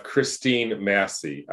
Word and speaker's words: Christine Massey: But today, I'm actually really honored to have Christine Massey.